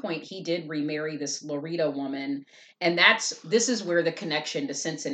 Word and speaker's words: Lauretta: Her father remarried a woman by the name of, and I think I'm pronouncing this right, it's spelled L-A-U-R-I-T-T-A. He remarried a woman, or I'm sorry, L-A-U-R-E-T-T-A is how it's spelled on her gravestone. He remarried a woman named Larita Ross, maiden name Ross, and she died in 1921.